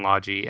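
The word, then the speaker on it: Logy: No, it's Eska and Logi, and I refuse to accept that those are the way that those two names are pronounced, and yet they are.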